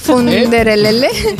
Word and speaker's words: Funderelele: Funderelele. No sé si lo digo, lo digo bien. Lo dices fenomenal, Funderelele.